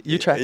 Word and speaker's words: Utrecht: I mean, the shows have been like, we played outside of Amsterdam in Utrecht. I don't know how to pronounce it, but you got it, Utrecht.